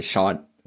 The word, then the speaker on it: Siân: I believe the name is Sean, you just pronounce it Siân Heder,